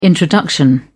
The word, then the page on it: introduction: British English